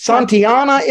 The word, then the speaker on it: Santayana: I mispronounced George, his name. I was on the. I said Santiago, but it is Santayana.